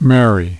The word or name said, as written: merry